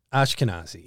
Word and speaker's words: Ashkenazi: So there's often in uh, Ashkenazi Hasidic lore. I hope I pronounced Ashkenazi. – Ashkenazi, yeah, Hasidic lore.